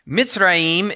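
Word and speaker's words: Mitzrayim (מצרים): Mitzrayim is how it's pronounced in Hebrew, and that just literally means Egypt.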